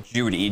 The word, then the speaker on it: Jeudy: And we think it's pronounced Judy, J-E-U-D-Y.